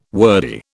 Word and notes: wordy: British English

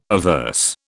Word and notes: averse: British English

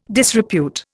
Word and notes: disrepute: Indian

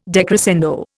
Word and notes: decrescendo: Indian

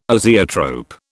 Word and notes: azeotrope: British English

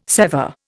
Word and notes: sever: Indian

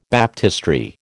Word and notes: baptistery: American English

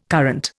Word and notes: current: Indian

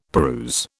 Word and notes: peruse: British English

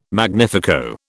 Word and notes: magnifico: British English

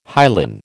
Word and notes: hyaline: American English

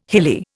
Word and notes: hilly: Indian

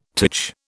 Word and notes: titch: British English

